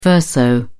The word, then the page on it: verso: British English